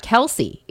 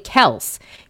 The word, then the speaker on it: Kelce: At any rate, their last name is not Kelce. It's actually Kels. You don't pronounce the C in it, obviously.